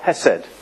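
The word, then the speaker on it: Hesed (חסד): It's a C-H-E-R-E-S-T-O-S, charestos. Okay, and the word in Hebrew is, what? Hesed.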